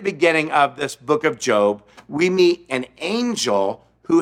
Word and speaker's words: Job: Spelled J-O-B, pronounced Job, not Job.